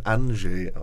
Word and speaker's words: Andrzej: Ich habe mir sagen lassen, den Vornamen spricht man Andrzej aus. – Ja. Das stimmt auch. – Ja, und nicht Andrzej oder Andrzej. Das sind einfach immer zu viele Konsonanten im Polnischen.